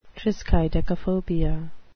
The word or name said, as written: triskaidekaphobia